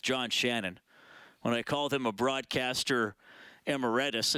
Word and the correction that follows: emeritus: and he's like, no, it's emeritus.